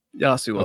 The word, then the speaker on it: Yasuo: Yes, Yasuo.